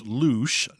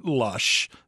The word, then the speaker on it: Louche: The fictional Louche, that's pronounced Louche, not Lush,